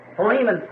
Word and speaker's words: Philemon: So, Philemon, someone said back there, which I think is really the correct pronunciation of it.